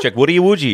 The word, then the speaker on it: Chukwudi Iwuji: It's Chukwudi Iwuji. – That's how. Yeah. Chukwudi Iwuji.